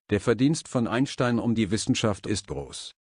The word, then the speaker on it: Einstein: Einstein 在德语我觉得发音像 Einstein， Stein 这个字超酷的呢，这个字可以当宝石呢，所以感觉像是一颗宝石呢。我们看例句， Albert Einstein 献身于物理学。